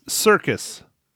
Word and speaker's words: Serkis: This movie is starring Robert Pattinson, Zoe Kravitz, Jeffrey Wright, Colin Farrell, Paul Dano, John Turteltaub, and Andy Serkis? Man. – No, you're right. It's Serkis.